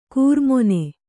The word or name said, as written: ಕೂರ್ಮೊನೆ